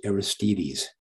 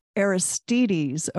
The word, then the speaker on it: Aristides: The pronunciation is Aristides. – Aristides, okay, thank you.